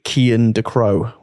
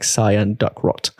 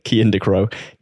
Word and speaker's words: Kian Ducrow: And I went to see Kian Ducrow, which is apparently how you say his name. I didn't know. I was calling him like Cyan Duckrot. Kian Ducrow.